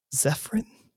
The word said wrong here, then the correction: Zephyrim: Yeah, Zephyrim. There's an M on the end.